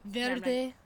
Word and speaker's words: verde: Verde. Verde. It's hard to roll the R on like a calm word like that.